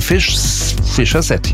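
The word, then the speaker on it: Fischer-Z: Ganz einfach, die Band war sehr lustig drauf und hat sich gedacht, wir nehmen einen Namen, der so ähnlich wie Fish's Head klingt. Ausgesprochen Fischer Z, ja, also Fischkopf.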